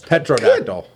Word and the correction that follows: Pterodactyl: No, Pterodactyl. Yeah, the P is silent.